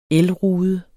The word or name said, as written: elrude